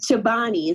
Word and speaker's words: Chobani: All right, Chobani, is that how you pronounce that company? – Yep.